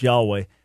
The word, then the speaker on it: Yahweh: So because if you're reading the Old Testament in Greek, the Septuagint, that almost 7,000 times where the Hebrew Yud-Heh, Vav-Heh, YHWH, which we normally pronounce Yahweh, but that name occurs almost 7,000 times.